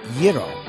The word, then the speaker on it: gyro: Yes, the greatest American gyro. Now I know how to pronounce it. That's exactly it. Drop the G and say it with finesse.